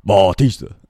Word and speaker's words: Bautista: It's just Bautista left. And Bautista. – I can't help it. – Bald headed Tista. – Bautista. Bautista. He's a wrestler.